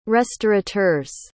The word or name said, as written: restaurateurs